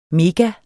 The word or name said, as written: mega